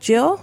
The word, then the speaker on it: gill: How about a gill? – Right. I think it's pronounced Jill.